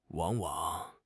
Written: wang3 wang3

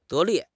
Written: 得力

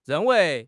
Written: ren4 wei2

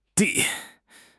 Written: de2

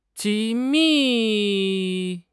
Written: 机密

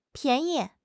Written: pian2 yi5

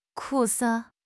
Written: 苦涩

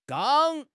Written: gang1

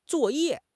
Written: zuo4 ye4

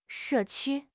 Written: 社区

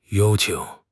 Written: yao1 qing3

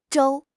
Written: 粥

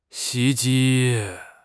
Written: 袭击

